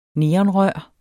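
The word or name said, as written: neonrør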